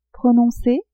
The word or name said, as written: Prononcez !